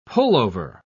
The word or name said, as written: pullover